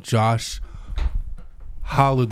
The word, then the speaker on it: Josh Halu: This is episode 112, and, to we, and today we have a guest all the way in psychedelic therapy realm, Josh Halu.